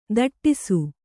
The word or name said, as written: ಧಟ್ಟಿಸು